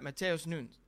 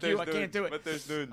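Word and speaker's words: Matheus Nunes: Silva, I mean, you're looking at, you've got Mateus Nunes. Sorry, I tried to say it like Mateus Nunes.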